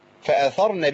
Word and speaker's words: فأثرنا: لا مفيش ألف. – فأثرنا به.